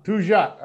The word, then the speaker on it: Peugeot: Peugeot.